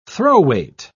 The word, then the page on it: throw-weight: British English